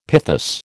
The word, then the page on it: pithos: American English